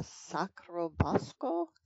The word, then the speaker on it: Sacro Bosco: So this book, In the Garden of Monsters, is mostly set in this garden called the Sacro Bosco. Is that how it's pronounced? And yep.